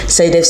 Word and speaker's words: SciDev: Saidev s'écrit S-C-I-D-E-V.